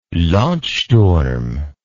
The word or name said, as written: landsturm